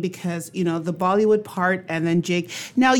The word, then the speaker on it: Bollywood: And in Bollywood Jig, Bollywood means, again, we know, to rhyme with Hollywood.